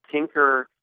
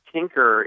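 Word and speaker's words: Tynker: Tinker is a terrific, it's inspired from Scratch from MIT.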